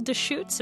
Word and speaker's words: Deschutes: One of the songs on here, Little Deschutes, am I saying that right? – Yes.